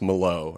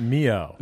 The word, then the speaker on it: Millot: It was Mark, and I saw in the script initially in the first episode that I was in, it was spelled M-I-L-L-O-T, and I decided that it was pronounced Malo, or that at least my character would pronounce it Malo. – Not Mio.